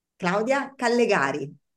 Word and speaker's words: Claudia Callegari: Claudia Callegari.